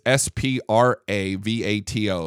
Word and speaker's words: Spravato: It's S P R A V A T O, Spravato.